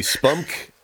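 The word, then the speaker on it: SPUMC: spunk. Is that how we're pronouncing it? – It is the most humiliating way to say it, so absolutely, spunk it is.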